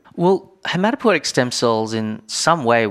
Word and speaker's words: hematopoietic: starting from hematopoietic stem cells. Did I pronounce that right? – Yes.